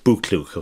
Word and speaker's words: BoKlok: It's spelled BOKLOK. It's pronounced Buklok, okay?